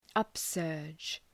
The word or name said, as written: upsurge